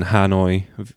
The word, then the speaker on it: Hanoi: And a similar thing to the anecdote actually happened in Hanoi. Is that how you pronounce it? – Yeah.